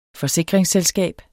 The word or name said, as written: FORSIKRINGSSELSKAB